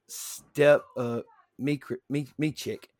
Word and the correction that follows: Stipe Miocic: Is that how it's pronounced? – Stipe Miocic. – Stipe, yeah. Vericio Verdum beat Travis Brown.